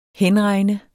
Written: henregne

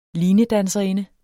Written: linedanserinde

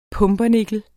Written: pumpernikkel

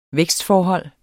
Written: vækstforhold

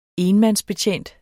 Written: enmandsbetjent

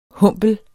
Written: HUMPLER

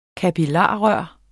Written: kapillarrør

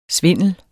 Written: Svindlen

